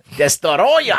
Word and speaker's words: Destoroyah: Destoroyah, if you pronounce it the way it's phonetically spelled. – Good pronunciation. – Destoroyah.